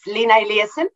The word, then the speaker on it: Lina Eliasson: It's Lena Eliasson.